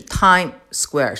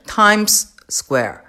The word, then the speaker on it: Times Square: 尤其是我们在发音的时候，一定记住这个不是 Time Square，是 Times Square。